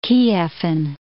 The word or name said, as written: Kievan